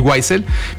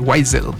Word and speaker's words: Weisel: Uriel Weisel, mi apellido, bueno, se le pueden preguntar a Alonso, pero es WAI Latina ZEL, Weisel. – Weisel, sí. Sí, se pronuncia así. – Weisel, si lo quieres germanizar. Mexicanizar es Weiser. Si es anglificar, Weisel.